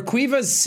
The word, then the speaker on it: Caoimhe: Cuiva's Caoimhe. So I'll spell it in. – What? – Yeah. – Caoimhe? – Yep. – That's Cuiva? – Mm-hmm.